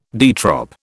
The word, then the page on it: de trop: British English